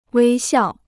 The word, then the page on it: 微笑: wēi xiào